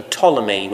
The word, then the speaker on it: Ptolemy: So in the south, there was a kingdom down there towards Egypt that was ruled by its first king Ptolemy with silent P.